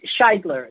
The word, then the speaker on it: Scheidler: It's not that hard. It's Scheidler. It's two syllables.